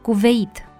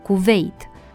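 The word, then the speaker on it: Kuweit: Tot greșit pronunțat, se aude frecvent numele străin Cuveit, în loc de pronunțarea în două silabe cu accent pe E, Cuveit.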